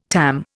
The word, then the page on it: TAM: Indian